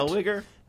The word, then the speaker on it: Zellweger: Renee Zellweger. Who? What? – Zellweger.